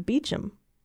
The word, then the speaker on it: Beauchamp: One was this detective whose last name was spelled B-E-A-U-C-H-A-M-P. And the other one was the murder suspect whose last name was spelled B-E-A-U-C-H-A-M-P. But one pronounced it like Beauchamp and the other one pronounced it Beecham.